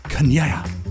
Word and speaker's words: Chania: No, it's not Kanye. – Here's my. It's spelled C H A N I A, which I would go Kanye. – Right. – Or Kania. And then the pronouncer is K double A N I Y capital A A.